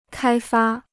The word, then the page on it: 开发: kāi fā